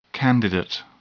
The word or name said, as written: candidate